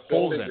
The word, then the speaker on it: Holzgen: The G is silent. – So it's Holden, right? – Holden. You got it.